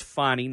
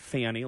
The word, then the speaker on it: Fani: Of course, Fulton County District Attorney Fani Willis pronounced Fani, not Fanny, like uh, I originally thought.